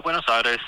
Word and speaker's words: Buenos Aires: Yeah, Buenos Aires. Mendoza's pretty cool. You know, the vineyards and stuff. – Can I just say, the way that you just pronounced Buenos Aires was very impressive.